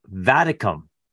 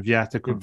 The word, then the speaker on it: Viaticum: So whichever way you want to say that, the Latin would be closer to the last one, Viaticum.